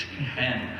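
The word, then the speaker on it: kehen (חן): If you look at it, C-H-E-N, but you don't pronounce it that way. Earlier, I was saying chen. That's not right. It's kehen.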